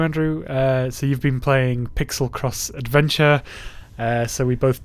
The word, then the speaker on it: Piczle: The Pixel, which is spelled P-I-C-Z-L-E, is